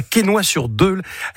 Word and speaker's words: Quesnoy-sur-Deûle: Tu habites à Quesnoy-sur-Deûle.